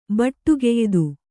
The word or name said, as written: ಬಟ್ಟುಗೆಯ್ದು